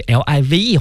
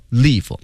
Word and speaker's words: live: 我们不仅呢要学习啊这个 transmitted 表示的意思是传送信号，咱们还要了解这个句子当中 transmitted live，大家把这 live 画下来啊，它不要读成 live，它读成 live。为什么？因为它是一个副词，修饰前面的 transmitted。